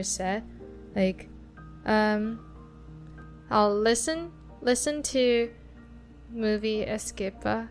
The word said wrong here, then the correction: escapade: How do you say that word? Escape? – Escapade. – Escapade.